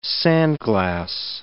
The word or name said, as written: sandglass